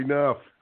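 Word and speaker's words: Knoff: He's a good friend of mine, Dr. Howie Knopf, although it's K-N-O-F-F. It's a silent K, Howie Knopf.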